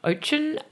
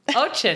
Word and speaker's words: Oetjen: They can find me at Ali, A-L-I, and my last name, Ochen, O-E-T-J-E-N. – Can I just admit something? – What? – We Googled how to pronounce Ochen. – Oh, did you really? What did it say? – Ochen. – Ochen. That's right. – There were varying things. Because I was like, I think it's Ochen, and Alex was saying, no, it's Weechen. – What? No. I've never heard that before. I used to love it because at school I used to want to be a marine biologist, and then I used to pronounce my name Ocean.